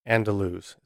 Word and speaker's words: Andaluz: So the next one I want to talk about is called Hotel Andaluz. It's spelled A N D A L U Z, Andaluz.